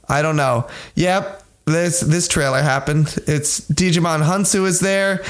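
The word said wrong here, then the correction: Djimon Hounsou: Jaimon Hansu. – Jaimon Hansu is there. – He said it rhymes with Simon.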